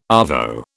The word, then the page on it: arvo: British English